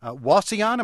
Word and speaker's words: Wauseon: Bill is with us from Wauseon. Am I pronouncing that right, in Ohio? – Yes, you are, Neil.